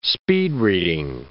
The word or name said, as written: speed-reading